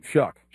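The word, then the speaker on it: Shough: Well, that's a guy I got my eye on, is that quarterback, and I can't say his name, Shuck. – Shuck. – Because it doesn't look like Shuck. It looks like Cho.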